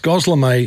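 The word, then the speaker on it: gözleme: Is it Goslame, Goslemi? How do you say it? It's Goslame, Hannah tells me.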